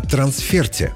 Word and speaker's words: трансферт: Словарь трудностей русского языка допускает обе нормы – трансфер и трансферт, но обязательно с ударением на последний слог.